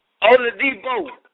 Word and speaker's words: Oladipo: Oladipo. – Oladipo.